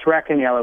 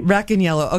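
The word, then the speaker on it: Racaniello: Is it Rossaniello or Rossaniello? – It's Rossaniello with a hard C. – Rossaniello.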